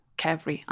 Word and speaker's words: qvevri: Q-V-E-V-R-I is a kevry, I'm pretty kevry.